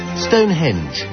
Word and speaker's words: Stonehenge: Stonehenge.